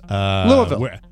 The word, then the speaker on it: Louisville: You don't say Louisville. There's no hard E. – You said Louisville. – It almost sounds like it's two syllables.